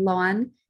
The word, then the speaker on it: Loan: And then um, growing up, I hated how people pronounce my name Lon because it's spelled L-O-A-N. Mm-hmm. So everyone would say loan.